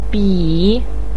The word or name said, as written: bi3